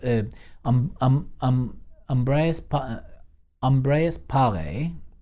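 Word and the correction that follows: Ambroise Paré: Uh, I think his name is Ambroise uh, Paré.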